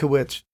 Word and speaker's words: Dutkiewicz: JB, you signed to Solid State Records in 2005, and then 2006 you recorded Thrill Seeker with Adam Dutkiewicz. Is that how you pronounce his name? – Yeah, that's correct. – Dutkiewicz.